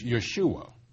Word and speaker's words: Yeshua: Actually, in Hebrew, it is Yeshua. Yes, just like that Yeshua, the one we call Jesus Christ.